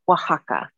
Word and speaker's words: Oaxaca: Is it like, oh, is it, how do you pronounce it? Because it's spelled O-A-X-A. – You've got it perfectly. – Okay. – Mm-hmm. Yeah, the spelling is not conducive to easy pronunciation for most of Western world. It is Oaxaca.